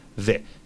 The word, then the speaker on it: the: Que es T-H-E. Es el artículo el, la, los, las. Vale para todo. ¿Ok? Entonces hay que fijarse cómo se pronuncia esa T-H. Es una D. Una D. Pongo la lengua entre los dientes y la hago vibrar.